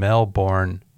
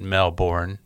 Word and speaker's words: Melbourne: If you're a 49er fan, you're looking forward to Thursday night as the 49ers play the Rams in Melbourne, Australia. It'll be Friday morning. It'll be Thursday night here. We pronounce it Melbourne, but they pronounce it Melbourne.